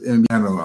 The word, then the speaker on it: Invierno: Ja, also das, das W, das wird wie Invierno, wie ein B eigentlich, Invierno ausgesprochen. Das B wie ein B, bei dem die Lippen sich nicht berühren, zum Beispiel Trabajar, Trabajar, Invierno, Trabajar.